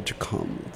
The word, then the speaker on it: Gqom: G-Q-O-M, by the way, and I'm pronouncing it correctly. – G-Q-O-M. – Yeah, Jikom.